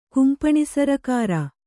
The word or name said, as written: ಕುಂಪೆನಿಸರ್ಕಾರ